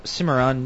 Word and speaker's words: Cimarron: So next we have Doug from Cimarron, New Mexico. – You pronounce that right.